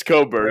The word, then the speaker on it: Cockburn: Wait, you just called him Cockburn? – It's spelled. That's how it's spelled. I don't know. – It is spelled that way, to me. – But it's Coburn.